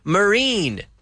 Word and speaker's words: marine: Marine. All right, marine. Very good. All right. Okay, we're gonna push for it. That's our pronunciation of the een. Let's say ine, right? Een is the sound.